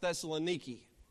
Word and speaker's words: Thessaloniki: Today, if you looked it up, it's pronounced Thessaloniki.